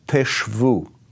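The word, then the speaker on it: teshvu (תשבו): Now, that phrase, you shall dwell, is translated from the Hebrew word teshvu. Teshvu, you shall dwell. It's spelled tav shin bet vav, teshvu.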